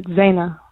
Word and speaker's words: Zeina: It's Zeina. Rhymes with Dana, so great.